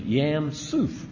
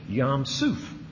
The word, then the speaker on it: Yam Suph: And this is translated, the pronunciation is Yam Suf. Y-A-M-S-U-P-H. Yam Suf.